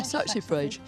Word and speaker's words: saxifrage: Yeah, saxifrage.